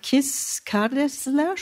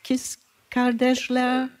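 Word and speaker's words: Kız Kardeşler: Der Beitrag Kis Kardeşler, spricht man es so, türkisch? Kis Kardeşler? – So oder so ähnlich, ja. Aber ich kann es nicht besser aussprechen insofern.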